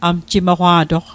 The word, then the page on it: amjimoqwa'toq: Mi'gmaq